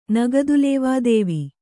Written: ನಗದುಲೇವಾದೇವಿ

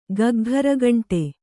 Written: ಗಗ್ಘರಗಂಟೆ

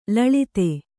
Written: ಲುಲಿತ

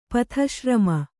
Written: ಪಥಿಶ್ರಮ

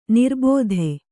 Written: ನಿರಾಬಾಧೆ

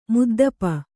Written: ಮುದ್ದಪ

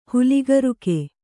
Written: ಹಲುಗಿರುಕ

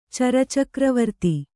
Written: ಚರಚಕ್ರವರ್ತಿ

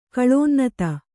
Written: ಕಳೋನ್ನತ

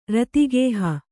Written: ರತಿಗೇಹ

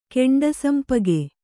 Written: ಕೆಂಡಸಂಪಗೆ

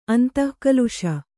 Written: ಅಂತಃಕಲುಷ

